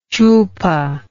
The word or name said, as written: chulpa